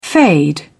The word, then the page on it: fade away: British English